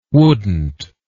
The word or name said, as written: wouldnt